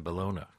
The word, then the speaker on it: Bologna: And I would think your first breakthrough was My Bologna, or Bologna, however you'd say Bologna, right. – Based on My Sharona. – Yeah, it rhymes with Sharona, right.